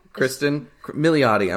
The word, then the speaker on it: Kristen Milioti: Well, Welcome back to Sounds Familiar, the podcast where we daydream about being stuck in a time loop with Kristen Malotti. – Is that how you pronounce it? – I'm assuming. Kristen Milioti. I'm sorry, I didn't see the second eye.